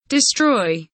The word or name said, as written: destroy